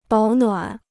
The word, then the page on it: 保暖: bǎo nuǎn